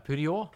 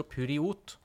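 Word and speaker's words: Poirot: Er det Purjo? – Det er Purjo. Det, det skrives jo Puriot. Ja. Jeg er så dårlig i fransk. Navnet på mesterdetektiven, men han synes, vil ikke, helst ikke kalles for Puriot, for han syns det ligner litt på idiot. Så han vil ha fransk uttale.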